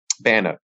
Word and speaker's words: BANA: Clover Nook is one of the founding members of the Braille Authority of North America, or BANA, or do they pronounce it as BANA? – BANA, yes.